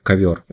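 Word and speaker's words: Kover: А, Причем мне понравился, знаешь, там такой мемчик, то есть кавер, то есть К первое, да, но по сути читается как ковер.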